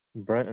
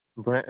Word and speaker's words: Brent: Brent Enriquez. – I'm sorry. Can you repeat that, sir? I'm sorry. – Brent Enriquez. – Can you spell that for me? – Brent is B-R-E-N-T. Enriquez is E-N-R-I-Q-U-E-Z. – And you say your last name was E-N-R-I-Q-U-Z or E-Z? – Q-U-E-Z, yeah.